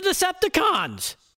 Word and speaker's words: Decepticons: The Decepticons. Well, it's with an emphasis on the last syllable, but you get my point.